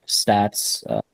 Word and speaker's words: Staats: My handle on Twitter is Rich Staats.